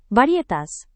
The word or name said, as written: varietas